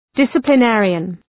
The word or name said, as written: disciplinarian